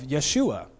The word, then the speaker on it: Yeshua: Now, it turns out Jesus is the English name of Yeshua. So if we really were going to pronounce Jesus right, we'd say Yeshua, kind of like the Hebrew Joshua, but we don't do that.